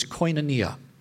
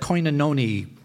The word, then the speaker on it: Koinonia: The name of it was Koinonia, a Greek word, of course, for fellowship. None of us knew how to say it. So on Long Island, everybody's saying, go to that Koinononi, whatever it is.